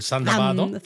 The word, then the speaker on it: Thunderbird: El Thunderbird, pero que los japoneses lo pronuncian en el Zandabado.